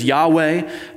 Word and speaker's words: Yahweh: Sometimes you'll hear that pronounced in the Hebrew as Yahweh.